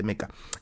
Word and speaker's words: Mecha: no se dice mecha, se dice meca.